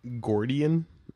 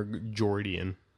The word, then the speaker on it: Gordian: Is it Gordian or Jordian? G-O-R-D-I-A-N. Is it? I don't want to do this anymore. Gordian. Jordian. – I would assume it's Gordian.